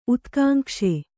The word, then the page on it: ಉತ್ಕಾಂಕ್ಷೆ: Kannada